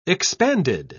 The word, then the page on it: expanded: British English